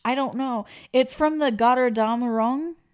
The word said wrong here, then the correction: Götterdämmerung: Which is German and I said it wrong. – Yeah. – Götterdämmerung.